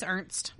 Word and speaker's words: Ernst: It looks like it was supposed to have been Ernest. It's not. It's Ernst. Yeah, okay.